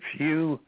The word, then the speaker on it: Hugh: It's Hugh H-U-G-H, your last name, Charlson, that's spelled T like Tom, R-A-U-L, S like Sam, E like Edward, N like Nancy.